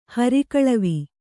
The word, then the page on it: ಹರಿಗಾಲುವೆ: Kannada